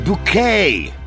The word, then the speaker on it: Bucket: All right, through your text, it's pronounced bouquet. Bucket.